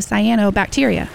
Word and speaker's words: cyanobacteria: If the lake is murky, has scums, or looks like pea soup, it might have high levels of cyanobacteria. Here's a trick. It rhymes with piano.